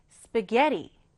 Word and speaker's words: spaghetti: 읽을 때는요, 강세를 게이 부분에 두시면 돼요. 그럼 읽을 때 spaghetti, spaghetti 이렇게 발음이 되겠죠?